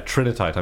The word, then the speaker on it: trinitite: No, I just said it wrong. Yeah, trinitite. I'm sure.